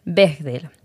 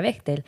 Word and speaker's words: Bechdel: Mira, primero se escribe B-E-C-H-D-E-L, Bechdel. Realmente no se pronuncia Bechdel. Antes de entrar he buscado cómo se pronunciaba, porque yo estaba segura de que era Bechdel, pero es como Bechdel. Creo que está en alemán.